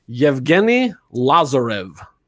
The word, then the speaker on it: Yevgeni Lazarev: He's known as Eugene by most of the people who worked with him, but Yevgeni Lazarev. was his name. – Oh, yeah. Oh, you did the hard G. That was good.